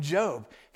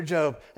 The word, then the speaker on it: Job: You see an example of that in the life of Job. If you go back in your Old Testament, there's a book in your Old Testament. It looks like Job, but we pronounce it Job.